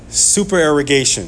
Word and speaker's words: supererogation: These were works of supererogation. I think that's how you say it. Did I say that right, Tim? Yes.